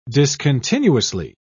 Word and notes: discontinuously: British English

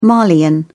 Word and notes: malian: British English

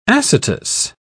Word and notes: acetous: British English

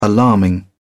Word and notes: alarming: British English

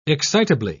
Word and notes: excitably: British English

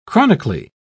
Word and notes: chronically: British English

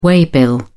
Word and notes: waybill: British English

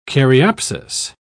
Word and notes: caryopsis: British English

